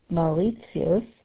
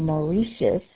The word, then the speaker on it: Mauritius: Now, as far as an island goes, that's not so much travel either, is Mauritius. Or Mauritius, as you guys pronounce it, and so it's off the coast of Madagascar, not too far from Madagascar.